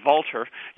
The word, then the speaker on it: Walter: I'm going to use the German pronunciation Walter, Johann Walter.